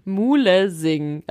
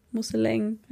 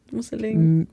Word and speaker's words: Mulesing: Mann, Mulesing. Aber das wird doch nicht so ausgesprochen. – Also alle, die jetzt Ahnung davon haben, die lassen sich. – Kann M-U-L-E. – Musseleng, heißt Musseleng.